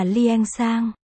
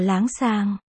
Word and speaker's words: Liên Sang: Tên đúng của y là Liên Sang. Cũng phát âm là láng sàng.